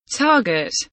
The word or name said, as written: target